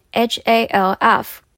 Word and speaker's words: half: 那如果是一半而已，一半大的话，就是 half the size of half h a l f。记得 l 不发音，所以是 half。